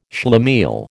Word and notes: schlemiel: American English

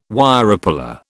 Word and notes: Wirepuller: British English

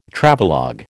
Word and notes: Travelogue: American English

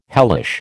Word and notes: hellish: American English

